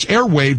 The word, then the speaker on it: Airwaav: Airwave is it's Airwave, but it's spelled A-I-R-W-A-A-V. So remember that W-A-A-V spelling.